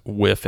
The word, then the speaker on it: Wipf: Whiff and Stock is spelled WIPF and Stock, Whiff and Stock Publishers.